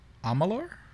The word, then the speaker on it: Amalur: Amalur? – Mm-hmm.